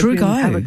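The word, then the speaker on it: Trugo: But let's talk to the President of the Victorian, now I hope I'm pronouncing this correctly, is it Trago or Tragot Association? How do I pronounce it? – It's true go. – True go. – Have a true go.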